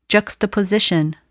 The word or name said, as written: juxtaposition